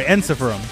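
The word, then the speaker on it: Ensiferum: Falkenbach, Einsiferum? Oh, sorry, Enciferum. – Say again? – There's no I after the R. – Enciferum. – Yeah, that's not what it's spelled as, though. – No. Okay. – That's why I said Enciferum, because it's R-U-M.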